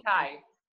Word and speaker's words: vitae: Vitae. Vitae. You want to hear it again? Listen. – Vitae. Vitae. – That's how you Vitae. Say it right there.